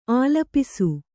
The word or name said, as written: ಆಲಾಪಿಸು